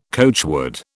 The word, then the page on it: coachwood: British English